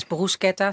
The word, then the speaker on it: bruschetta: Is, het is bruschetta. CH is altijd, altijd. uitgesproken als een K.